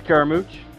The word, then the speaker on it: Carmouche: This Friday, Showtime, Bellator, the Women's Flyweight Championship will be decided between Juliana Velasquez and Liz Caramucci. Is that did I say that correctly or Caramucci? – Liz Caramooch.